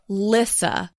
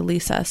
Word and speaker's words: Lissa: But you pronounce her first name Lissa. – Lissa, which is hard because it's spelled L-I-S-S-A. Lissa. I don't know. For some reason in my head, I just want to say Lisa so badly.